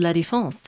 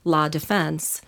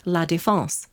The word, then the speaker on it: La Défense: Like, there's a place in Paris, it's called La Défense. And when you hear a narrator saying, it's spelled just like the English word defense. If you hear La Défense, that's flat out wrong.